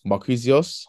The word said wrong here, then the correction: Marquinhos: How'd you say his name again? – Marquinhos, what, from PSG? – Yeah, PSG, that's it.